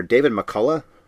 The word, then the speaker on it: David McCullough: The narrator, David McCullough, is how you pronounce his name.